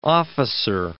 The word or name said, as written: officer